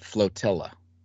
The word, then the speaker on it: flotilla: Discovery River, like the other little cavalcades I have there, which I always think it's tortilla, but flotilla.